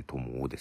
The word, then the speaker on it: TOMOO: ともですけども、えー、 TOMOO と書いてともですね。